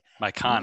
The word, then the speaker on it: myconid: How do you pronounce? This is a word that I've seen a million times in red, but I'm scared I'm pronouncing wrong when I read it out loud. My condit. The myconid. – Myconid.